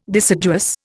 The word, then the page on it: deciduous: Indian